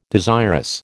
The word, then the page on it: desirous: American English